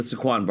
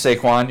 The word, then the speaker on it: Saquon: This is basically the Saquon Barkley Award. – First of all, it's Saquon.